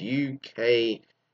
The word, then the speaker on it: ukulele: It's UKULELE. So it's ukulele. Lalela. You would think it'd be Lay Lee, but it's, yeah.